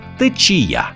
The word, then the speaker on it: течія: Течії наголошуються на перший склад лише в множині. А в однині всі відмінки цього слова мають наголос на кінець – течія.